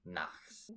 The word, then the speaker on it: Nachts: Yeah, it's Knights in German. – Yeah, Knights. Pronounce it Knights? – No, it's Nacht is night in German. – Ah, okay. – So it's 1001 Nachts.